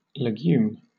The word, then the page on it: legume: British English